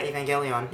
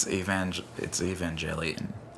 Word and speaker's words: Evangelion: I can't remember if it was the original or the second rebooted iteration of the Japanese anime Neon Genesis Evangelion. – Evangelion. Is that how they say it? – Yeah, Evangelion. Yeah, and... – It's Evangelion.